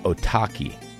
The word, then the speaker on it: Otaki: I've been obsessing with the name Otaki since Sportiva released the slick blue shoe a couple years ago. Rhymes with souvlaki.